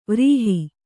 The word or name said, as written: ವಿರಹ